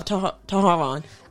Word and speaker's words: Tehran: They marched down the street in a suburb in in a suburb of Tehran. I'm mispronouncing your capital's name. I'm sorry. It's T E H R A N.